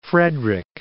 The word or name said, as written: Frederick